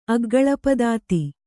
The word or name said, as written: ಅಗ್ಗಳಪದಾತಿ